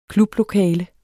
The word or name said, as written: KLUBLOKALE